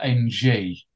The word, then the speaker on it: Ng: Finally, I've just got to say The listeners, you just need to keep an eye on this trainer, Pierre Ng, and his surname spelled NG. Okay, so when I say Pierre Ng, I am saying the correct pronunciation.